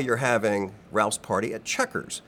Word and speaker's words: Chequers: A little interesting story behind Checkers. It's spelled C-H-E-Q-U-E-R-S, Checkers with a Q.